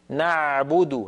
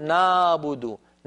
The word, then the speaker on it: نعبد: من جملة ذلك عين كلمة نعبد، لا تنطقوها بارك الله فيكم عالية نعبد هكذا نا، بل هي أعمق قليلا. العين الفصيحة أعمق من العين التي تعودنا في العاميات أن ننطقها. نعبد هكذا العين، نعبد نعبد، ولا نقول نابد نا، هذا صوت فموي ليس له مدلول، أقرب إلى الألف هو.